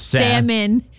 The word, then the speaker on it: salmon: It's salmon. – Salmon. – Salmon. – But if I'm pronouncing the L, why wouldn't I also pronounce the O? Wouldn't that make more sense? – You don't do it in the other way.